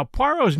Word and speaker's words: Poirot: Now, Poirot's name appears spelled differently in The Murder of Roger Ackroyd. It appears as P-O-R-R-O-T, not P-O-I-R-O-T, as it does elsewhere.